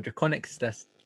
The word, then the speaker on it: Drakoniques: People pronounce it Draconix, and I guess that technically is how you'd normally pronounce it, but no.